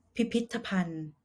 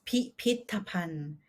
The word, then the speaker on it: พิพิธภัณฑ์: Next word, พิพิธภัณฑ์พิพิธภัณฑ์ As you can see, you might think this word pronounced like พิพิธภัณฑ์ but actually as a Thai native, we will say พิพิธภัณฑ์ which means museums. For example, where is the museums? พิพิธภัณฑ์อยู่